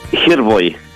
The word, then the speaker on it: Gerboy: Gerboy, como sí, si tuviera acento en la E.